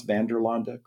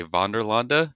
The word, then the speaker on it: Vanderlande: I mean, most people say Vanderlande to get it, I guess, as accurate as possible. Where we are, I've heard all kinds of pronunciations from Vanderlande to Vanderland. I mean, it's all there.